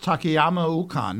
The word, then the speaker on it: Takayama Ukon: Well, this is Eustace Takayama Ukon.